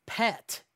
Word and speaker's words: pet: Farts are called pet in French Canadian, like the name Brett, starting with a P.